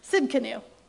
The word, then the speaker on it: Tsidkenu: Tsidkenu, the T is silent.